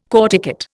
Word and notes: corticate: Indian

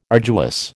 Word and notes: arduous: American English

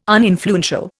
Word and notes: uninfluential: Indian

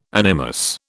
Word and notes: animus: British English

